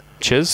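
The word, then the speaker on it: chizz: Chiz. – Spell it. – Chiz. – It's C H I Z Z. C H I, not jizz.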